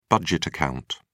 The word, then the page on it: budget account: British English